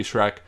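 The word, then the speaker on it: Schrec: Shrek, spelled like my last name, I'm not an ogre. That is The Real B-S-C-H-R-E-C.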